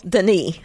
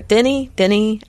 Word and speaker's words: Denis: Well, Denis, I think is, you're supposed to say it. Or Denny, I don't know. – Drop the anyway. S, silent S.